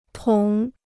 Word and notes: 捅: tǒng

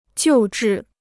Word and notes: 救治: jiù zhì

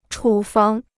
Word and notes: 处方: chǔ fāng

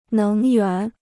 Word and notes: 能源: néng yuán

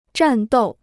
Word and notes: 战斗: zhàn dòu

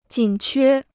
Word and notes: 紧缺: jǐn quē

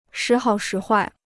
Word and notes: 时好时坏: shí hǎo shí huài

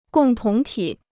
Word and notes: 共同体: gòng tóng tǐ

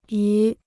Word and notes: 鱼: yú

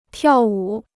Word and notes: 跳舞: tiào wǔ

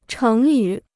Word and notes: 成语: chéng yǔ

